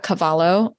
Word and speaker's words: Cavallo: That's my main platform, at Laura Cavallo _ cravings _ coach.